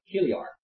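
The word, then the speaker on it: chiliarch: For instance, in chapter 21, verse 33, and the chief captain came near, it says the Kiliarch is pronounced, the Greek pronunciation, the CH is a guttural sound, Kiliarch.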